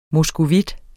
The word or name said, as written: moskovit